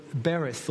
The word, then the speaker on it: berith: In the Hebrew language, the word for covenant is the word bereth or beret, depends which Hebrew school you went to. It's pronounced B-E-R-I-T-H.